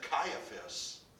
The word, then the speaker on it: Caiaphas: His son-in-law, Caiaphas, some pronounce it Caiaphas,